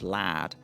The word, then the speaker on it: lard: So one of the other ingredients for bread she talks about is lard, which she pronounces as lad, and that's still very much the pronunciation right.